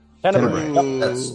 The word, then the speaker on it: Tenebrae: I'm going to go with Dario Argento's, I never pronounce this movie right, Tenebrae. – Tenebrae.